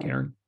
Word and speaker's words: cairn: So make sure you head out to Prospect Point, grab a drink, find the cairn. Cairn? Am I saying that right? – Cairn. – C-A-I-R-N. Cairn. Cairn. Yeah.